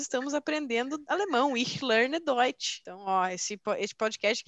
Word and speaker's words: Deutsch: Deutsch. – Deutsch.